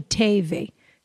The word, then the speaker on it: Tavi: It's actually Tavi, rhymes with gravy.